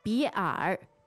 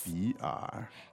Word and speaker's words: 比尔: 比尔. 对. – Two-third tones? – Yes. – 比尔.